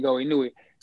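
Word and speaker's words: Inoue: Yeah, he's on the Inouye. – Inouye, there you go. – Inouye.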